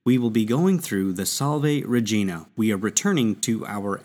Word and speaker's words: Salve Regina: So the Salve Regina, which is pronounced Salve Regina in ecclesiastical Latin, means Hail Queen.